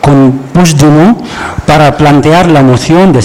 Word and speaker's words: Puigdemont: No, Puigdemont. – Vale. Puigdemont. – Eso es. – Sí, lo dices vale. Como francés, así.